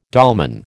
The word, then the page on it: dolmen: American English